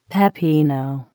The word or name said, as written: pepino